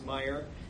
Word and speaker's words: Meyer: an effort to make sure everyone knows how to pronounce that Dutch name, but it is pronounced Meyer, and it's a family-owned company, and the family takes great pride in the business they've built since 1934.